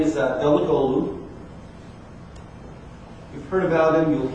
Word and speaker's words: Davutoğlu: When you see that mark, it means that G's silent. So you don't say it. So this is how you say it. I just said So Davutoğlu is Turkey's foreign minister.